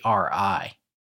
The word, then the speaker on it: Kanashibari: And I can't remember if we talked about this before, but they call it the Kanashibari or Kanashibaro. I'm not sure how to say it. Mm-hmm. Kanashibari. – Yes.